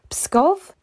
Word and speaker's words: Pskov: If I've pronounced that incorrectly, yeah, probably. Pskov? That sounds vaguely right.